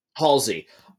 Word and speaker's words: Halsey: I could not stand the scenes with Dr. How do you pronounce her name again? – Halsey.